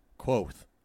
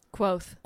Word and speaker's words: Kvothe: I've settled on Quoth. – Quoth. – Quoth. It's spelled K V O T H E.